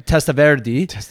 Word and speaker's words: Testaverde: Testaverde.